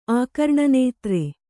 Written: ಆಕರ್ಣನೇತ್ರೆ

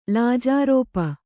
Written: ಲಾಜಾರೋಪ

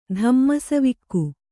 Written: ಧಮ್ಮಸವಿಕ್ಕು

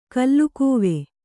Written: ಗಲ್ಲಗಿವಿ

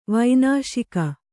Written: ವನಶಿಖಿ

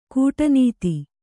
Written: ಗಾಡಿನತ್ತು

